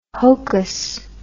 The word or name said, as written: hokus